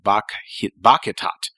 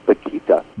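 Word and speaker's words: Bakhita: But here's another person who is just an absolutely heroic figure, and that is Saint Josephine Bakhitat. If I'm pronouncing that correctly. – Actually, it's Bakhitat. – Bakhitat. Bakhitat. Yes.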